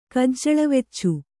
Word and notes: ಕಜ್ಜಳವೆಚ್ಚು: kannada

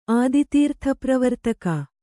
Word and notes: ಆದಿತೀರ್ಥಪ್ರವರ್ತಕ: kannada

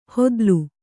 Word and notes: ಹೊದ್ಲು: hodlu

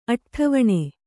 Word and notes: ಅಡ್ಡವಣೆ: aḍḍavaṇe

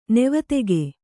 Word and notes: ನಿವೇದಕ: kannada

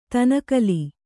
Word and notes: ದನುಕುಲ: danu kula

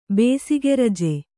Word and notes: ಬೇಸಿಗೆರಜ: bēsige raja

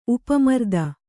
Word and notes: ಉಪಮರ್ದ: upa marda

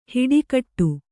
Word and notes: ಹಿಟ್ಟುಗುಟ್ಟು: Kannada

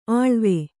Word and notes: ಅಳವೆ: aḷave

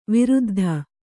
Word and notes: ವೃದ್ಧೆ: vřddhe

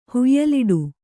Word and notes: ಹುಯಿಲಿಡು: huyiliḍu

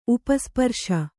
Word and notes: ಉಪಸ್ಪರ್ಶ: upa sparśa

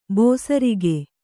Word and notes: ಬೇಸರಿಕೆ: bēsarike

